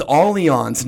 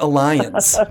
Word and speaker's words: Allianz: It is Allianz, not Alliance, just for the record.